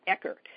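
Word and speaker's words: Eker: I think I'm pronouncing his name right. Yeah, Eckert.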